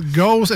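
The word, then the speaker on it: Gose: gauze ou une gaza, si on veut bien le prononcer à l'allemande. – Gaza. – Gaza. Ah avec un accent sur le O.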